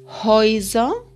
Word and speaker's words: Häuser: S, esas vocales se pronuncian juntas hoy, como en hoise, casas, y Moise, ratones.